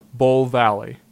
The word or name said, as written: Bull Valley